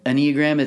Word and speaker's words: Enneagram: The Enneagram is something our church uses, but it's not a religious thing necessarily. But pronounce it again. – Enneagram. It's Enneagram.